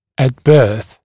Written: at birth

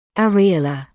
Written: areolar

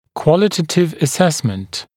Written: qualitative assessment

